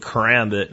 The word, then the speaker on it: karambit: Let's start out with a karambit, and I think that is the right way to pronounce it, by the way.